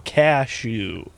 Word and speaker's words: cashew: Put it all up front. Cashew. – Cashew.